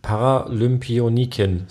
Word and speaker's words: Paralympionikin: Paralympionikin. So wird es ausgesprochen.